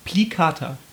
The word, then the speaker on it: plicata: Lingua plicata, oder? – Mhm. Plicata. – Mhm. – Ja. – Okay. – Nein, du musst das Spanisch aussprechen. Plicata. – Lingua plicata. – Genau, das ist Spanisch.